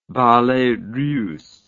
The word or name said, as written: Ballets Russes